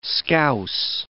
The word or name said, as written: Scouse